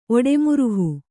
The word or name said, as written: ಒಡೆಮುರುಹು